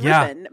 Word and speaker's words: Rippon: I love that you pronounce it like Poupon. It's Rippon. But Rippon, just Rippon.